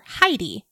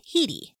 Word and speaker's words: Heidi: Something interesting about her name too, it looks like when you're looking at it on paper, Heidi. However, it is pronounced Hedy.